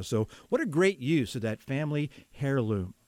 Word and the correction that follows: heirloom: I'm sorry, that's a silent H. That's heirloom.